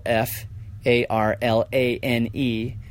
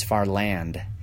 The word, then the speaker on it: MacFarlane: My ancestors dropped the A out of Mac and it's not far lane, it's far land.